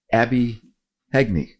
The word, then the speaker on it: Heagney: Today we have a very special guest, Abbey Heagney. did I pronounce it right? – You did, yes, Heagney. oh, great.